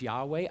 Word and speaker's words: Yahweh: A lot of modern scholars argue that the most likely pronunciation was Yahweh.